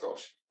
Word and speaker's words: Grolsch: I still think it's Grolsch. I think Grolsch is how you say it. That's how I'd say it.